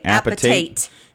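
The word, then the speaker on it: apatite: Appetite. That's how you say it. Appetite.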